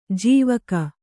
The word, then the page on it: ಜಾವಕ್: jāvak